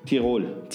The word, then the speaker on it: Tirol: And you pronounce it Tyrol? – Tirol.